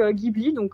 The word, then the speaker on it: Ghibli: Alors, c'est Ghibli Fan France, donc Ghibli, comme ça se prononce, Ghibli, .fan.france, comme la France, FRANCE.